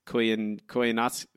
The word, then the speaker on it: Koyaanisqatsi: Koyaanisqatsi.